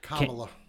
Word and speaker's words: Kamala: Kamala.